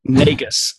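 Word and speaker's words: Nagus: I mean, so it's N-A-G-U-S, and how do you pronounce that? – Negus.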